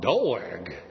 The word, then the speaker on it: Doeg: Sounds like a southern pronunciation of dog. Doeg.